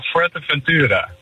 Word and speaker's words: Fuerteventura: Ja, Fuerteventura.